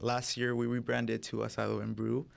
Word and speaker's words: Asado: Am I saying that right? Asado? – Yeah, Asado and Brew Festival. – Asado, awesome. Just like that.